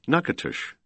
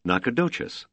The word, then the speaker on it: Nacogdoches: For the sister town in Louisiana, Nacogdoches had been pronounced French-style, Nacatush, while only a few miles to the west, its sister town was called in the Spanish manner, Nacogdoches.